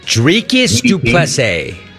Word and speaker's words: Dricus du Plessis: How do you say his name? – Dricus du Plessis.